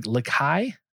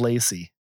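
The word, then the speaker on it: LaCie: It's like Lakai. I could probably look this up before doing this, but I don't know. Lacy? I think it is Lacy. That makes a lot more sense now that I'm actually like phonetically sounding this out.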